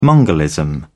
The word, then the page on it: mongolism: British English